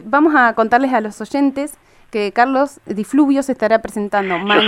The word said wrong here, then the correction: Di Fulvio: Yo soy Carlos Di Fulvio, no Di Fluvio.